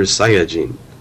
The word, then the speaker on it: Saiyajin: the correct pronunciation, Super Saiyajin. – He does. – That is the Japanese pronunciation.